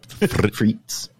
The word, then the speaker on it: frites: But you don't pronounce the. Yeah, it's just frites.